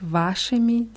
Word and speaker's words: вашими: С вашими детьми, bei вашими ist es auf A, bei детьми ist es auf dem letzten I. С вашими детьми.